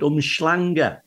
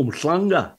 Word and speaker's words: Umhlanga: But the South Africans, the white South Africans, call it Umhlanga, right? Oh. But the Zulus, whose language it is, go Umhlanga. And it sounds like our double L sound.